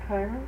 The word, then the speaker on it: Cairo: C-A-I-R-O. – Cairo? – Is that how you pronounce it? Or did you ever hear of the town? – I've heard of it, but I've heard it pronounced Cairo.